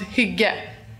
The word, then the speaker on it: hygge: I had to ask her how to pronounce this word that looks like higgy. She helped me out. – So it's pronounced hygge. Hygge.